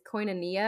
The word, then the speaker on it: koinonia: The original word for that fellowship, the Greek word for it is koinonia. I think I pronounced that right. – You did. Good job.